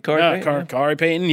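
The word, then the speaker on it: Khary Payton: I don't want to butcher Kahari Payton. Did I butcher that? – He nailed it. – Kahari Payton, yeah.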